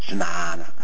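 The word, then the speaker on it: jñāna: I should say it right, jnana. We have Sanskritist in this temple, and so when everybody uses the, says jnana, jnana, jnana, you know, like which is a Hindi, Bengali jnana, but the actual Sanskrit is jnana, jnana, jnana.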